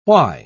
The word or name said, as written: why